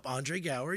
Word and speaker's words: Andre Gower: I have an apology for Andre Gower. – Andre Gower, yes. If I'm saying it right. – Yep, Andre Gower, you're because, right.